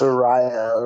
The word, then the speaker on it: Arayo: It's Arayo. It rhymes with Ohio.